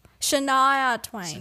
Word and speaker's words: Shania Twain: Is it Shania Twain? – Shania Twain.